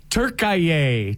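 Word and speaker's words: Türkiye: Turkaye. Turkaye is the way Fox Network is officially saying it in their promos.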